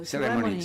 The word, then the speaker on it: Ceremony: o se i Master della cerimonia, Ceremony, Sereni, vabbè, Ceremony. – Giusto, l'hai detto giusto? – Ceremony, Ceremony, esatto, mi inceppano le cose.